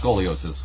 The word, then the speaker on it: scoliosis: You know, freaking uh, scoli or you know, no, I'm sorry, is that? I think I said that wrong. Is it scoliosis? – Yeah, I think it's scoliosis, curvature of the spine.